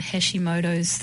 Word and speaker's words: Hashimoto's: Now, just to sort of give a bit of background, you specialize in a really particular form of thyroiditis called Hashimoto's thyroiditis.